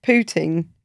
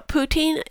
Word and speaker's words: poutine: do you use your cheese for pooting? Is that the correct pronunciation? – Uh, yes, it is. But uh, poutine is kind of a waste of curd cheese.